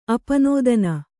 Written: ಅಪನೋದನ

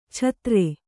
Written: ಛತ್ರೆ

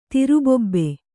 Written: ತಿರುಬೊಬ್ಬೆ

